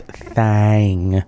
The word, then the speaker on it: Thing: Uh, before that, we heard Babes in Toyland doing He's My Thing, which is spelled T H I N G, even though it is uh, pronounced Thang in the song, but I'm glad they spelled it correctly.